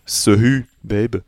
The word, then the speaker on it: Sehubabe: Mais ça s'écrit S-E-H-U-B-A-B-E, See You Babe.